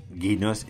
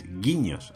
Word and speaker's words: guiños: Y donde has dicho guinos es guiños, aunque yo creo que tú la ñ no la sabes pronunciar.